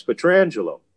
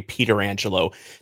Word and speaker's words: Pietrangelo: he'll tell you it's Alex Petrangelo."